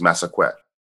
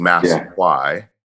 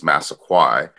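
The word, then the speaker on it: Massaquoi: It's West African. It has multiple pronunciations, but the original pronunciation is Massaquet. But in English, it is Massaquai, transition to more modern English, it's Massaquai. But overall, a lot of people normally assume it's French.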